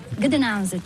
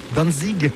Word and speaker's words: Gdańsk: Gdańsk, c'est le nom slave. Gdańsk. – Gdańsk, que nous, Français, prononçons en disant Danzig.